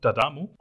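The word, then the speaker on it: D'Adamo: Mein Nachname wird ja geschrieben großes D, Apostroph, großes A und Damo. Also es wird ein Wort ausgesprochen, Dadamo.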